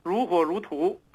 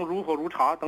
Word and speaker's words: 如火如荼: 这个如火如荼读成如火如茶等等，其实都有同样的问题。